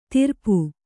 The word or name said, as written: ತಿರ್ಪು